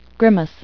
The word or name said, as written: grimace